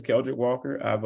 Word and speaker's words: Keldric Walker: Well, you got the name right. It's Keldrick Walker.